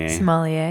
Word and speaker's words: sommelier: Sommelier. – Yeah, Tom. You know what I'm talking about? Do you guys see? – I don't remember this episode. No. – Tom is like looking for a sommelier for his bistro that he's opening,